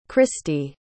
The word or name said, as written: christie